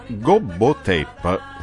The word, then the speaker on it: GoBoTep: Senta, innanzitutto, GoBoTep, non so se l'ho pronunciato bene, non è inglese, non è altro che una trascrizione sui generis dell'espressione bergamasca GoBoTep, letteralmente che significa, e mi dica anche la pronuncia esatta. – No, no, lei l'ha pronunciato esattamente.